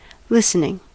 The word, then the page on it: listening: American English